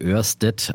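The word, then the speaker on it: Ørsted: Und seine Aktienidee Nummer drei war die Orsted oder Örsted auf Dänisch ausgesprochen, Orsted geschrieben, ähm, die wir auch schon öfters diskutiert haben, äh, auch sehr bekannt.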